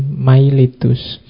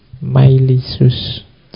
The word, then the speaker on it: Miletus: Meskipun tulisannya Miletus. Kalau pakai lidah Inggris biasanya dibacanya Miletus. Tapi pakai lidah Yunani Miletius.